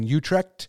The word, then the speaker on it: Utrecht: They currently live in Utrecht.